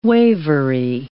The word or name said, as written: wavery